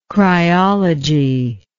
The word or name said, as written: cryology